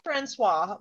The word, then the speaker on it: Francois: F-R-A-N-C-O-I-S. – Okay, so I would say Francois, but since we are in America and people are awful, it could just be Francois.